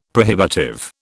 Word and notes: prohibitive: British English

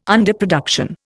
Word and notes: underproduction: Indian

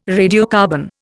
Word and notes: radiocarbon: Indian

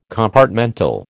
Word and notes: compartmental: American English

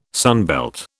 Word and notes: sunbelt: British English

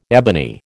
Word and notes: ebony: American English

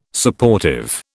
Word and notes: Supportive: British English

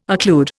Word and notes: occlude: Indian

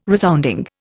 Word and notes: resounding: Indian

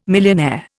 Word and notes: millionaire: Indian

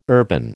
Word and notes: urban: American English

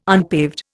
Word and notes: unpaved: Indian

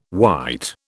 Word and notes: wight: British English

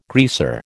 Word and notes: greaser: American English

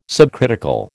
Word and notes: subcritical: American English

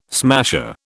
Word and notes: smasher: British English

